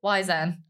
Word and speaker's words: Wizen: It's called like We Sean or something. – That's it. Wizen. – How do you pronounce it? – Wizen. – Oh, right. I said We Sean.